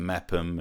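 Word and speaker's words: Meopham: Meerfirm being pronounced Meppham, and Rootham is...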